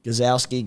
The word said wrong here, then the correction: Gajewski: Gazowski. – Gajewski. – Gajewski.